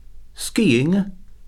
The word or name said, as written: skiing